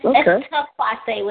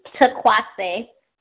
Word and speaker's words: Taquase: It's Taquase with a T. Taquase.